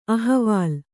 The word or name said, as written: ಅಹವಾಲ್